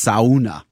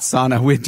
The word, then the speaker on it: sauna: As predicted, somebody has corrected my pronunciation. It's sauna. – Sauna. – Not sauna. Jenny and I were laughing about that, and then sure enough, thank you, listener. I know I don't say it like the Finns. It's sauna. Finnish language has an awful lot of vowels in it.